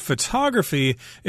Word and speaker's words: photograph: This word photography is a little tricky here because photography is the art and science of taking pictures, but uh, your resulting product is called a photograph with the accent on the first syllable, but in the word photography, the accent is on the second syllable.